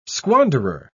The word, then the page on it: squanderer: British English